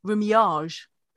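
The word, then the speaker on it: remuage: Now, since the 1970s, this process has been mechanized, and this is called riddling or I've got to get the pronunciation right, rumiage, which is a French word for riddling.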